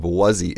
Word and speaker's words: wuzzy: But wuzzy, the pronunciation of wuzzy is exactly the same as was he.